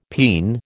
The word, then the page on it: peen: American English